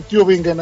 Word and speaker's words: Tübingen: Buenos días, sí, sí, lo pronunciaste muy bien, Tübingen en Alemania, lo dijiste muy bien.